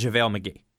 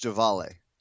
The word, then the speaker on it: JaVale: To Denver for Oklahoma City's protected 1 to 18 protected first in 2015 and JaVale McGee. I believe it's pronounced Javale.